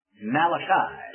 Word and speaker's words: Malachi: As we look at the title, I remember someone pronouncing it Malachi, not Italian, it's Hebrew, Malachi.